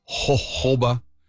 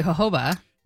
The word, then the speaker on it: jojoba: It has rosemary, citronella, tea tree, geranium, jojoba, and chamomile. – I like the way you emphasize jojoba. – You said it correctly, jojoba.